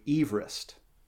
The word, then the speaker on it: Everest: Fun fact number one, Everest was not how you actually pronounced his name. You pronounced his name Everest.